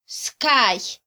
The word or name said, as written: skay